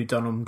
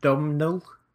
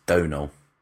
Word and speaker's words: Domhnall: Is it Domhnall? – No, it's Donal. – Donal. Okay. – Yeah, exactly like Donal Logue. – Right. – But spelt with, you know, an M and an H for kind of Scrabble cheating reasons, possibly.